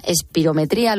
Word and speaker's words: espirometría: Una espirometría, ¿lo he dicho bien? – Sí, mm. perfectamente.